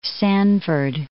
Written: Sanford

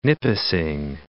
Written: Nipissing, Lake

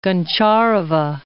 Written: Goncharova

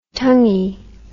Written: tonguey

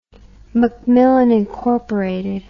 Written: macmillan inc

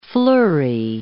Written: fleury

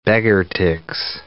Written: beggar-ticks

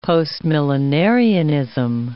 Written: postmillenarianism

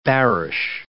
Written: bearish

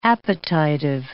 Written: appetitive

